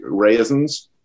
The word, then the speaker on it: reasons: By the way, do you know that about that line, give me my reasons, Jack, that probably in the dialect it would have been pronounced raisins?